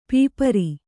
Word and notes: ಪಾಪರ್: kannada

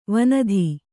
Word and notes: ವನಧಿ: vanathi

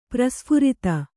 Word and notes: ಪ್ರಸ್ಫುರಿತ: prasphurita

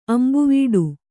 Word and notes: ಅಂಬುವೀಡು: ambuvīḍu

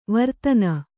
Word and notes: ವೀರತನ: vīratana

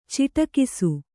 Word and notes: ಚಿಟುಕಿಸು: ciṭukisu

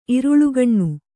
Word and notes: ಇರುಳುಗಣ್ಣ: iruḷugaṇṇa

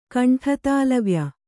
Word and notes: ಕಂಠತಾಲವ್ಯ: kaṇṭhatālavya

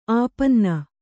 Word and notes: ಆಪನ್ನ: āpanna

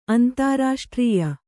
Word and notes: ಅಂತಾರಾಷ್ಟ್ರೀಯ: antārāṣṭrīya; kannada

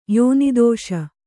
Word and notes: ಯೋನಿದೋಷ: kannada

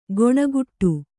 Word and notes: ಗೊಣಗುಟ್ಟು: goṇaguṭṭu

